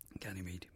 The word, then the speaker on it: Ganymede: Ganymede, yeah.